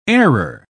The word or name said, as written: airer